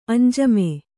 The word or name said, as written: ಅಂಜಮೆ